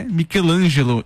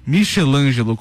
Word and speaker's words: Michelangelo: Fala, e agora, depois do erro da pronúncia do nome dele, eu preciso dizer quem é Michelangelo e não Michelangelo, como foi dito no bloco anterior.